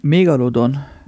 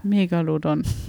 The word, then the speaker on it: Megalodon: Ich kann das Wort nicht aussprechen. – Megalodon. – Megalodon.